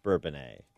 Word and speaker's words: Bourbonnais: Today, it is pronounced Bourbonnais. So it may have been Bourbonus, the fur trappers in the 1800s, but it's Bourbonnais today. So let's just stick with that.